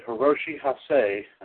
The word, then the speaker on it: Hiroshi Hase: 28 years ago today, here, 1988 in Sendai, Japan, the late Owen Hart defeated Hiroshi Hase.